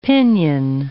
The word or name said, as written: pinion